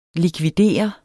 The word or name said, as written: likvider